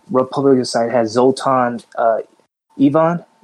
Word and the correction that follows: Istvan: I believe I pronounced it right. – Istvan. Sure. – It's Zoltan Istvan.